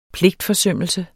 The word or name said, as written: pligtforsømmelse